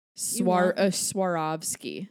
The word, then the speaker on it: Swarovski: Is it Swarovski? – Yeah. – Swarovski.